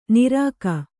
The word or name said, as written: ನಿರಖ್